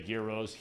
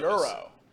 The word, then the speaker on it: gyros: You some people say gyros, heroes. It all depends, right? – Euros. – Euro. – Well, so, how do you say it? – Euro. – Euro? – Like the currency.